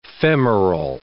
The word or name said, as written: femoral